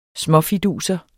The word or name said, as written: småfiduser